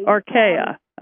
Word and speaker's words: Archaea: Let me see if I'm pronouncing it correctly. Archaea, is that right? – Archaea, probably. – Archaea, okay.